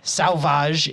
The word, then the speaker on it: Sauvage: This is the Nola Brewing Sauvage. Sauvage? S-A-U-V-A-G.